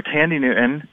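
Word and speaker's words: Thandie Newton: I just I really loved Sandy Newton, or Tandy Newton is how her name is actually pronounced, from Westworld.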